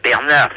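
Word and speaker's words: Bernart: Bernard, si, c'est mon prénom, mais ça s'écrit pas comme vous me le prononcez.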